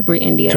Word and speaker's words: Brei Endia: It's spelled E-N-D-I-A. So it's okay. B-R-E-I. – Okay. – E-N-D-I-A. Brei Endia.